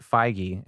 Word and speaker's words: Feige: They announced recently, and we have a Variety article in front of us, that Kevin Feige, that's how you pronounce it, Feige, and it's like F and then eyeball, Gee.